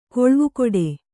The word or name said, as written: ಕಾಲುವೆಗಟ್ಟು